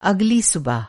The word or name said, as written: सुबह